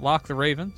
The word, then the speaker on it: LocktheRaven: You can find me on Twitch and Twitter at Locke the Raven, spelled like it sounds.